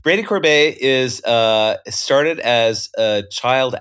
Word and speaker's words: Corbet: He pronounces his last name Corbet. The way I used to pronounce David Mamet's name when I was growing up. Ooh, who's this David Mamet? – Like sorbet. – Sorbet. – not sherbet.